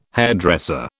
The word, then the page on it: hairdresser: British English